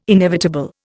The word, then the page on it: inevitable: Indian